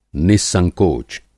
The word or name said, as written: नि:संकोच